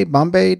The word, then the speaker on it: Bombe: National Raspberry Bombay Day. Bombay Day. B-O-M-B-E Day.